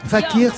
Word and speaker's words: Fake Ear: Fakir, ça s'écrit Fake Ear.